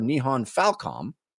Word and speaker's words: Nihon Falcom: The JRPG series in question is The Legend of Heroes by developer Nihon Falcom.